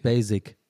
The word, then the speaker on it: Basic: Das ist eher ein schärferes S und nicht ein weiches S. Basic, okay. Sag mal, nicht Basic.